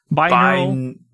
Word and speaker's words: binaural: How do you pronounce? Is it binaural? Biannual. – Binaural. Binaural. It's binaural, right? – Binaural.